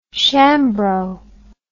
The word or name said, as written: shambro